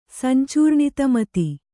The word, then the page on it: ಸಂಚೂರ್ಣಿತಮತಿ: kannada